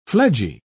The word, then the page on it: fledgy: British English